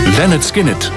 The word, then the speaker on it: Lynyrd Skynyrd: Lynyrd Skynyrd, zo spreek je dat uit.